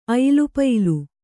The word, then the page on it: ಅಯಿಲುಪಯಿಲು: Kannada